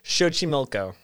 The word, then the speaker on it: Xochimilco: So is it Lake Xochimilco or Lake Xochimilco? – It's Lake Xochimilco.